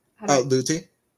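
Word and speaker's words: Lüthi: And it sounded like from a kind of commitment and travel schedule, it was similar with, how do I pronounce his name? Lutheran? Luthri? – Luthi? Severin Luthi.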